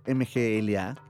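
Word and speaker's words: Mgła: Y si por ahí les suena como algo medio conocido, pues la voz es el de Mgla, que es MGLA, el banda Black, que, es, que, se, se pronuncia Mgla, Entonces pues no es porque estamos como así como mal, pero es MGLA. Ellos ya son los de Old World Rapes.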